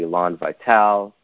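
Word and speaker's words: élan vital: So I was very attracted to the notion of the Elan Vital, that's how you pronounce it correctly, Bergson.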